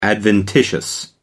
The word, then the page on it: adventitious: American English